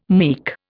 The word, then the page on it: meek: Indian